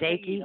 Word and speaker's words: Dake: No, Dake. – Is it Dakey? – No, that's right. No, just Dake. – Dake. Okay. – Most people want to put an R in it for some reason, but it doesn't have an R.